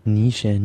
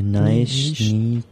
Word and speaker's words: niche: Ja, also N-I-C-H-E. Wie würdest du das aussprechen? Nische. – Nische, Nische.